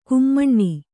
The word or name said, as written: ಘುಮ್ಮನೆ